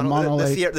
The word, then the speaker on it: Mono Lake: Yeah, Mono Lake.